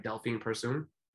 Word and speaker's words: Delphine Persoon: I was ringside for her fight with um tell me if I'm pronouncing her name right, Delphine Persoon. – Yeah. Mm-hmm. Okay. – Perfect.